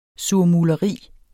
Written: surmuleri

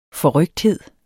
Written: forrykthed

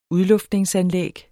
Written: udluftning

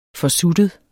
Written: forsuttet

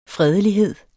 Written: fredelighed

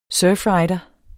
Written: surfrider

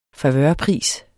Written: favørpris

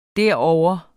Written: derovre

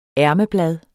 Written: ærmeblad

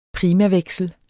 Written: primaveksel

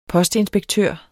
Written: postinspektør

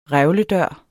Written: revlen

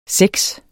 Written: seks

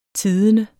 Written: tidende

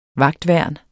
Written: vagtværn

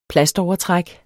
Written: plastovertræk

